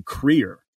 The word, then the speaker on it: Kruger: The way that you would pronounce Kruger's name in Afrikaans is actually Kreer.